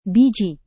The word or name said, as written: biji